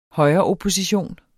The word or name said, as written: højreopposition